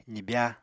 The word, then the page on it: zibya: apatani